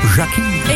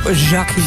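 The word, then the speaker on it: Jacqui: Het is een J, een A, een C, een Q, een U en een I. Dus ik kom uit op Jackie. – Ik zou ook uitkomen op Jackie. – Jackie.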